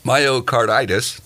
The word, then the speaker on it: myocarditis: The rates of myocarditis. Is that how you say it? Myocarditis? – I think you got it, yeah.